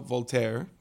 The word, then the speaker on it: Voltaire: Lo había puesto en Google y puesto, ¿cómo se dice en español? Voltaire o Voltaire. V-O-L-T-A-I-R-E.